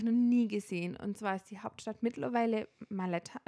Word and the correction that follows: Valletta: äh, Valletta.